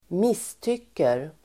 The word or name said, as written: misstycker